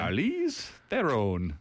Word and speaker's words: Charlize Theron: It's neither of those. I've heard her clarify the pronunciation of her name before, and it's, you know, she's South African. – Charlize Theron.